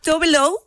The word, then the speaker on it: Tove Lo: Tove Lo, es la pronunciación, pero su nombre real es Eva Tove Elsa Nilsson.